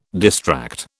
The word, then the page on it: distract: British English